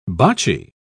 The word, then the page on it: bocci: British English